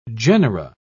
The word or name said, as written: genera